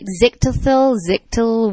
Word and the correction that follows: xylitol: whatever. That's the substance in gum that is very, very poisonous. Xylitol. – Xylitol. – Yeah. That's it.